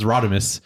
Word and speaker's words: Rodimus: Rodimus. – Yeah, you got it. – Yeah, that's Rodimus.